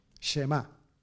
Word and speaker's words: Shema: Shema. ¿Puedes repetir conmigo? Shema o Shema en, en pronunciación hebrea, pero nosotros con acento Shema, ¿no es cierto?